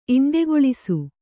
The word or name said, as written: ಇಂಡೆಗೊಳಿಸು